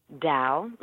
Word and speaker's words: Tao: Tao. Mm-hmm. It's pronounced like D-A-O.